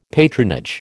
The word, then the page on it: patronage: American English